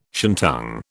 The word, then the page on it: shantung: British English